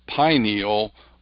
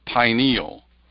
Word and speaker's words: pineal: That's why I say the proper pronunciation of the word is pineal, like pine, pine cone, pineal.